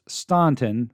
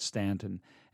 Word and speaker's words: Staunton: And our address is 1743 West Beverly Street, and it's actually properly pronounced Staunton, but here in these sticks, we call it Stanton.